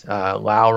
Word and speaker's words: Lowe: I think it's Brandon Lau, even though it's Lowe, and everyone else pronounces that Lowe. Yeah, it is. Lau rhymes with now is what they even put rhymes with now on Baseball Reference.